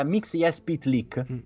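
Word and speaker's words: Mxyzptlk: Sì, ma fondamentalmente si pronuncia, almeno dopo hanno fornito l'esempio, è molto simile alla pronuncia straniera fatta da un giapponese. Cioè ci metti ogni tanto qualche vocale in mezzo per riuscire a pronunciare. Infatti diventa Mixia Spitlick.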